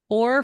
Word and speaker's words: hoar: So there is a hoar frost shape like flowers. It's spelled H-O-A-R, okay, like boar.